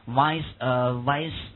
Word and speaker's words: Viceroy: Lord Curzon, Viceroy, 啊，注意那个念 Viceroy.